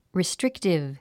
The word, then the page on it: restrictive: ristríktiv; リィストリクティブ